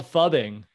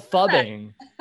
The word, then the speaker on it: phubbing: I think it's called fubbing. where you essentially blow someone off for your phone. – Is that, but how do you spell that word? With a P? – P-H-U-B-B-I-N-G.